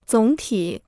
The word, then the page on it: 总体: zǒng tǐ